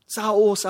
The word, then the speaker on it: Saul: Here really the translation is, Saul, 사오, 사오. 왜 그렇게 하냐면 그건요, 히브리 어족으로 사울이라는 이름을 부를 때요. – This is the pronunciation of Hebraic name, Saul. Not Saul, like a Grecian name.